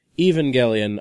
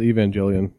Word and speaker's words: Evangelion: I was pronounced it Evangelion because Evangel. – Evangelizing. Right. – Well, you can have evangelical people or an evangel. Yeah, that makes sense. But it's they do the hard G for whatever reason.